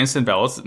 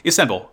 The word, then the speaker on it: Istanbul: It's there in Istanbul. Why did I say it like that? Istanbul.